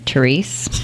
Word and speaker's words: Thérèse: And I'm, even though the saint that it's sort of based on is French, I'm using American pronunciation and calling her Thérèse.